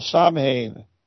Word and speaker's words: Samhain: Its origins certainly aren't Christian, having spawned from the ancient Celtic festival of Samhain. Let me spell that. S-A-M-H-A-I-N.